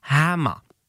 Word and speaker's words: hammer: Chytrá umělá inteligence, nebo možná už ne tolik umělá, říká Hammer. – Hama. Zkus to vyslovit. – Hama. – No vidíš, to, jak je to krásně jde. Ani tam není to R.